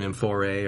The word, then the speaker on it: amphorae: And now we have the mythology behind the amphorae. Is that how you say it? – The amphora is a singular, and amphorae are all of them.